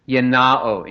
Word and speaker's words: gennao: Yenao in Greek, G-E-N-N-A-O. And there's another word, yinome, in modern Greek pronunciation, G-I-N-O-M-A-I, yinome, means to become, to come into existence.